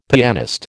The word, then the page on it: pianist: American English